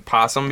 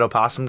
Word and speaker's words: opossum: And then also, just to avoid something from earlier, you do not pronounce the O in possum. No, I have heard a lot of people say the O I just wanted to say it in possum before, but you don't pronounce it.